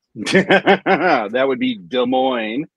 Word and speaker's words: Des Moines: I think Des Moines, the S is silent.